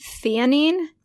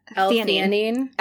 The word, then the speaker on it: theanine: I've been taking, I'm going to say this wrong. This is like the hyaluronic acid for you, but it's for me. Theanine? – Yes. – L-theanine.